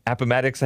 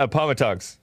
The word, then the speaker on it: Appomattox: I used to call it Appomattox. But there's some listeners who live in Appomattox, I think is what it's pronounced. And it's not Appomattox.